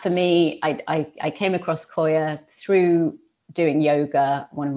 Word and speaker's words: Qoya: Koya. So Koya, and that's spelled Q-O-Y-A, and it's a movement practice designed for women.